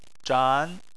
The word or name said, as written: zhan2